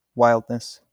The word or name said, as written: wildness